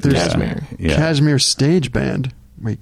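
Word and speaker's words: Kashmir: Yeah, Cashmere. Yeah, yeah, that's how you say it.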